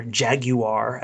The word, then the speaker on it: Jaguar: Maybe it's the company Jaguar, or Jaguar as they pronounce it there.